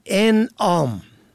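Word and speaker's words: اِنعام: ان آم و ان آم، این دو واژه در تلفظ و معنا متفاوت است. انعام با فتح اول جمع نعم به معنای چارپایان است، اما انعام با کسر اول به مفهوم بخشش است.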